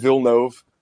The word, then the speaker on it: Villeneuve: I I I think every decision that uh, Villeneuve, that's apparently Gogs showed me an interview with him, and that's how you say his name, Denis Villeneuve.